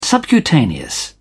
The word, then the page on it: subcutaneous: British English